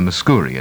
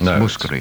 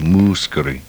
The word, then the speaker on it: Mouskouri: Some wonderful colleague in Sydney was talking about the Nana Mouskouri programme and mispronounced a name, called a Mouskouri, and it's not. It's Mouskouri. – It's Mouskouri.